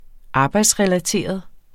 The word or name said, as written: arbejdsrelateret